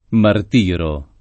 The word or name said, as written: martirare